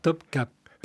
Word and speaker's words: Topkapı: I est sans point, donc non. C'est Topkapı. Oui.